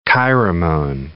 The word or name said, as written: kairomone